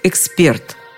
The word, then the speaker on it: эксперт: Правильно, конечно же, эксперт, экспертный. Словари дают именно такое ударение, как единственно правильное. Вариант, который прозвучал в телестудии, сопровождается восклицательным знаком и пометой «не рекомендуется».